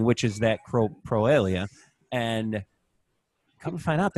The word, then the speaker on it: coprolalia: I was doing some research and I came across where they explained, I think I'm pronouncing it right. Coprolalia. Is that right? What is it? – Coprolalia.